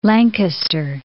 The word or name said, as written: Lankester